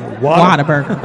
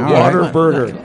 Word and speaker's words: Whataburger: Is it water or water? – It's pronounced Whataburger. – Whataburger. – Like water burger.